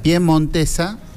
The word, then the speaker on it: piemontesa: Jurado, yo, un error del sistema, Bustos, de apellido, en la sociedad piemontesa, fui, lo dije bien, piemontesa. – Piemontesa. – Sí, es pie, con pie, es pie, piemontesa. – Es al pie del monte, claro.